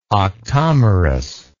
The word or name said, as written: octomerous